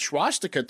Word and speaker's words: swastika: Swastika. No, it's it's just SW. – Yeah, you're adding a whole sound. – Yes, you're adding an SCH before the W.